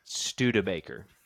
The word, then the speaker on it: Studebaker: Studebaker.